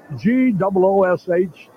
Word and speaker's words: Gwosdz: Now I want to ask you about wouldn't you rather have this name spelled G O O S H the phonetic spelling rather than all the S's, W's, and Z's. – Gives him some character, though. G-W-O-S-D-Z is the spelling, but it's pronounced Goosh.